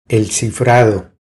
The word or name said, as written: El cifrado